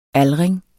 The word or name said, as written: aldring